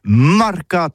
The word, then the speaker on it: marcat: Mais en gascogne toulousain, c'est prône sur les marcats, avec un A marcat.